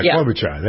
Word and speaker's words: Klobuchar: Or how do you pronounce her name? I think it's Klobuchar. The senator from Minnesota. – Amy Klobuchar. – Yeah, Klobuchar. That's it, yeah.